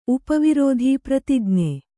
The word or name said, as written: ಪ್ರತಿಜ್ಞೆ